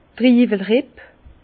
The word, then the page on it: d' Triiwelripp: Bas-Rhin; Herrlisheim